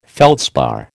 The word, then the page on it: feldspar: American English